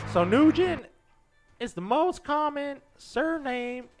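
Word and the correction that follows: Nguyen: Oh, win. Pronounced win. – Oh, pronounced win? – N-G-U-Y-E.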